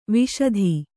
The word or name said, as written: ವಾಶಿತ